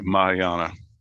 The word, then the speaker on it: Mahayana: But talking about some of these other different things, polytheism, he talks about folk religion, which is animism, Hinduism, Mahayana. I don't even know if I pronounce it right. – Mahayana.